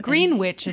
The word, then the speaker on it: Greenwich: Greenwich is how they pronounce it.